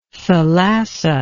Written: thalassa

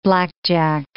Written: blackjack

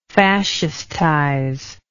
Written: fascistize

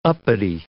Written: uppity

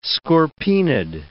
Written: scorpaenid

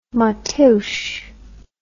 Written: Mateus

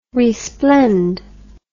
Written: resplend

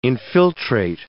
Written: infiltrate

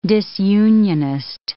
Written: disunionist

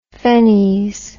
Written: phenies

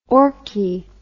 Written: orchi

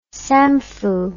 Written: samfu

